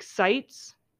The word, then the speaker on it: Seitz: Patrick Seitz. Is that how you pronounce his last name? – Mm-hmm. – He said it and we were like, oh, we were saying it wrong. Was it Seitz?